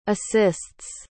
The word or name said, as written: assists